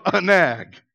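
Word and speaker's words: anag (ענג): The Hebrew word for delight is spelled A-N-A-G, anag.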